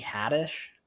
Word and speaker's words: Haddish: Tiffany Haddish rhymes with radish.